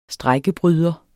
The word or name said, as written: strejkebryder